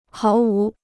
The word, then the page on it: 毫无: háo wú